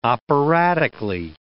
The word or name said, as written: operatically